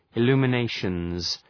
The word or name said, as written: illuminations